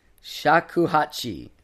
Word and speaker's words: shakuhachi: So I'll spell it out. S-H-A-K-U-H-A-C-H-I. – Shakuhachi. – Sure, Shakuhachi.